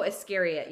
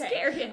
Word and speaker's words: Iscariot: Are you saying Iscariot or Aristocrat? – Oh, Iscariot, yes. – I was like, I don't remember Judas being an aristocrat. It's Iscariot.